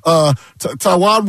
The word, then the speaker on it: Taijuan: But first things first, they get Taijuan Walker, and we found out that it is Taijuan Walker is pronounced like the country.